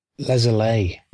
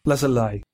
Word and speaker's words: Le Soleil: Am I saying that right? Lesolay. – Lesolay. – Yeah, okay. I think.